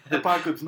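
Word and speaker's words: Apokolips: Apocalypse, not spelled the way you'd think Apocalypse would be spelled. – Yeah. – But pronounced the same way.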